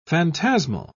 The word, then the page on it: phantasmal: British English